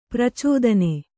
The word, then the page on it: ಪ್ರಚೋದನೆ: pracōdane